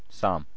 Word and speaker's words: Psalm: Psalm. – Psalm. – The P is silent.